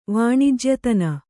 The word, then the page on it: ವಾಣಿಜ್ಯತನ: kannada